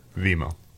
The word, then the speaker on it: Vimeo: So they put this documentary up on Vimeo. – Vimo. – Vimo, sorry. Appreciate that. I've been saying it wrong.